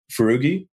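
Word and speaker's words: Foroughi: This guy, Adam, how do you say Adam's last name? – Farugi. – So Farugi, Adam Farugi, he's the CEO now.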